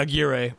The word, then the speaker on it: Aguirre: Yeah, Aguirre.